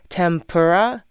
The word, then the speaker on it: tempura: There's a few more words like this, various like foods, for example, like tempura in English is spelled T-E-M-P-U-R-A. – Yes. Right. – In Japanese, it's ten, T-E-N, pura. – Tempura. – There's no M. – Right. – Where did that M come from? – I know.